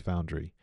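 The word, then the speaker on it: Found:RE: I've wanted to go before, and so you know, I don't like pretension, and I hate the name because it's Found colon R-E. So it's supposed to be pronounced Foundry.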